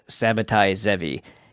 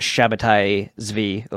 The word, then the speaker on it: Sabbatai Zevi: but very few people have ever heard of Sabbatai Zevi, and some people pronounce it Sabbatai Zvi or whatever, but it is what it is, who declared himself to be the Messiah in 1666.